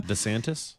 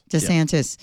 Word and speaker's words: DeSantis: And then the Republican ticket is going to be broken up so far in the primary between Ron DeSantis. Is that how you say his name? Governor of Florida? – DeSantis? – DeSantis. Yeah.